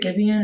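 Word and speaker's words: Giveon: I haven't listened to, is it Gibeon? Is that how you pronounce it? Yeah.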